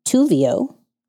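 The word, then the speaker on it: Thuzio: and Tuvio, that's how you say it, which produces influencers, events, and experiences.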